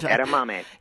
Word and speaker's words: edamame: Edamame.